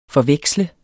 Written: forveksle

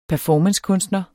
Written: performancekunstner

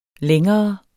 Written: længere

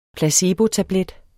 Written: placebotablet

